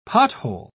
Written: pothole

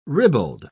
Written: ribald

